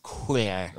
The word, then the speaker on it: couloir: Yeah, it's a couloir for sure. Like right. Pipeline at Snowbird when you get off the tram. That thing's... – Couloir. Oh, say it like a Frenchman.